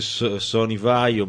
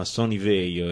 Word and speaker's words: VAIO: Ho scoperto solo dopo che non si dice Sony Vaio, ma Sony Veio.